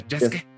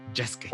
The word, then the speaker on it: Yasuke: se escribe Yasuke, pero se pronuncia Yasuke. Yasuke. – como Sasuke. Y, como Sasuke, ajá, exacto, como Sasuke.